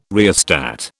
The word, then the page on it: rheostat: British English